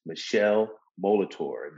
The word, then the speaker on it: Michele Molitor: And today we have none other than Michele Molitor.